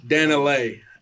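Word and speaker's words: Dani Leigh: So last week we seen the homie Dani Lay, I hope I'm saying her name right. – Dani Lay.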